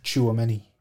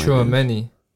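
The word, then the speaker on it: Tchouaméni: I've heard it pronounced Chuameni. – Oh my. Chuameni. – That's how I've heard it. – That actually sounds right. Yeah, that makes sense. Tushameni. Makes more sense.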